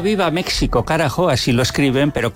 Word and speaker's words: México: Entonces, vamos a recopilar que es México con J o con X escrito, preferentemente para dar gusto a los mexicanos con X, pero la pronunciación siempre con J, incluso que sea cantando.